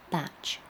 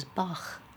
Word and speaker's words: Bach: Anyway, it's pronounced Batch Flower Remedies. It says so in the introduction. For years, I thought it was Bach.